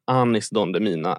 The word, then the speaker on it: Anis Don Demina: Så här, om man inte känner till honom, Anis Don Demina är hur det uttalas.